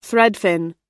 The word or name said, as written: threadfin